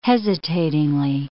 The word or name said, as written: hesitatingly